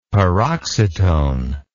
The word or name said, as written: paroxytone